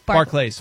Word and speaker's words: Barclays: Is it Barclay? – Barclays. – Barclays. – But is it pronounced Barclays Center? – No. – Barclays. No, Barclays. Okay, that's what I thought.